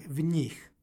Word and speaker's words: v njih: I imamo nj izgovor, dakle v njih.